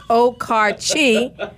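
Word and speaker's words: Okarche: Okay, so the verdict is it's Okarchi and it's Ishens for all those pronunciations for Oklahoma City.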